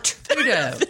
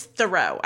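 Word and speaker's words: Theroux: Thoreau? Is that how you say it? – Thoreau. – Not Trudeau. – Not Trudeau. No. It's Thoreau, I do believe.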